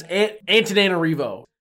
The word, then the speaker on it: Antananarivo: Because apparently Google says it's Antananarivo.